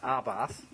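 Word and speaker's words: Abarth: Is it Arbath? – Arbath. It's pronounced Arbath. – It's Scorpio. – But it's A-B. A-B-A-R-T-H.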